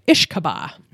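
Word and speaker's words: uisce beatha: So she tells us that the word whiskey comes from a classical Irish Scottish Gaelic term, and it's spelled U-I-S-C-E space B-E-A-T-H-A, and she said it means water of life, but the loose pronunciation sounds something like ishkaba.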